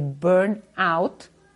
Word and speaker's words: burnout: mucha cosa parecida a lo que es el burnout.